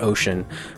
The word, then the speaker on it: Ochion: I mean, the truth is it's spelled different. It's O-C-H-I-O-N, and it sounds like ocean, but it was my great-grandfather's name.